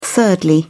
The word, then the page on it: thirdly: British English